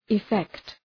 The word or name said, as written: effect